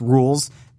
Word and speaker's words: Rouxls: I also like that they called his battle theme here, it's pronounced Rules, because that was a big debate when Chapter 1 came out that no one knew how to pronounce his name. It's spelled R-O-U-X-L-S, as in like a curry roux, for example.